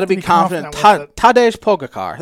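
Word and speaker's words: Tadej Pogačar: Tajay Poga Car. That's way off. Yeah, you bungled that one.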